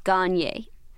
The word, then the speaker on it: Gagne: So just so we know, Aaron is on the message boards, and Aaron has just informed me that it's pronounced Gagne. – I was right? – Aaron Gagne. – Like like Kanye West? – Yes. Almost like Kanye West or K-A-G-N-E.